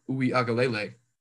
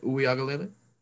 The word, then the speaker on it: Uiagalelei: Our boy Young Concrete, Mateo Uiagalelei. Got for today as well. – That was pretty good. I like that pronunciation you just got there. I think it was pretty close. – Uiagalelei? – I thought it had like an Uiagalelei.